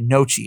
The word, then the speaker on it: gnocchi: Recipes like chicken ramen, they've got a turkey ragu ganache. Is that ganache? No, I'm not saying that right. Gnocchi. It's gnocchi, isn't it? Ganache is not that word. G-N-O-C-C-H-I.